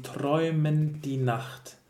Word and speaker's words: träumend die Nacht: Uh, it's erwartet sie träumen die Nacht. So um, träumend die Nacht. It's not possible, not necessary to pronounce both.